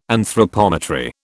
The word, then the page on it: anthropometry: British English